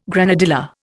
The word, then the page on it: granadilla: Indian